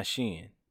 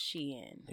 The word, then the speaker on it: Shein: That's how you pronounce that? – Yes. – So it's not Shein? – No. It's Shein. – Interesting. – Yeah. Like Shein, like actually has.